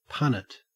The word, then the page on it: punnet: Australian English